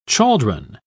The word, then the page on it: chaldron: British English